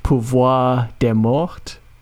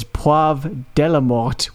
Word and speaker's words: pouvoir des morts: So instead of saying pouvoir des morts, which is power of the dead, he says poivre de la mort, which means pepper of the dead.